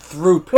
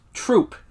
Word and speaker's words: Throop: Oh my God, when Dwight said Throop. – Okay, so it is spelled Throoop. It is a town, is a suburb of Scranton. However, it is pronounced Troop. – Like a troop of soldiers.